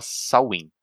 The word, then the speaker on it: Samhain: Que, que, se, que, que, se, fala, se escreve Samhain, S-A-M-H-I-A-N, uhum. só que se fala Samhain.